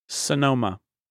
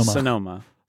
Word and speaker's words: Sonoma: Is that like the way you're saying, like phonetically, is it like S-A-N-O-M-A? – I mean, that's how I say it. Sonoma, not Sonoma. Sonoma. It's softer. It's Sonoma. It's not Sonoma. – Sonoma. – Sonoma. – Sonoma. Like Panama. Sonoma.